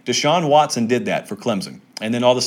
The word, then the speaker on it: Clemson: Clemson with an S, of course, even though I pronounce the Z.